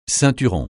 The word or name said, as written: ceinturon